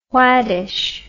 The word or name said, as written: swadish